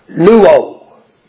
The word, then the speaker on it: luo: But the word washed in the past tense is the Greek word luo. Luo, that's the way you pronounce it.